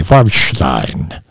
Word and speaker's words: Farbstein: I'm pronouncing it the German way, Farbstein.